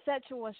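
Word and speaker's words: Sekyiwa: Sakiwa Shakur? – It's actually Setua Shakur. Setua. I know it's spelled weird, but yeah, but it's pronounced Setua, like pronounced like with a T instead of a K.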